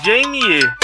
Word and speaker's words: Jamie: É, se é, você falou James. É Jamie. Jamie Fox.